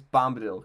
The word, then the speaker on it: Bombadil: But I'll never forget that his name's Bombadil, because that's how it's spelled.